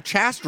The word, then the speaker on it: Chast: Chast rhymes with cast, unmistakably, right? – Chast rhymes with cast, yes.